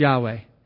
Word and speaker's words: Yahweh: So it's most commonly pronounced in English, Yahweh.